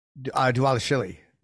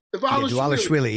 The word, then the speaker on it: Dvalishvili: Dvalishvili. – Dvalishvili, yeah.